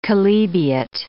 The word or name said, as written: chalybeate